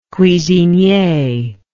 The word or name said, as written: cuisinier